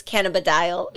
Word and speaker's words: cannabidiol: So CBD is short, and tell me if I'm saying this wrong, cannabidiol oil? – Uh-huh. It's very close. Cannabidiol.